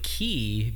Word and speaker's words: ki: I think I go with ki because I picture playing like the Budokai games.